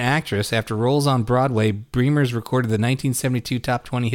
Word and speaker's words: Bremers: Uh, but here's the exciting thing about it is that Bremer's, uh, eh, her surname is pronounced Bremer's, rhymes with dreamers, even though it's B R E M E R S.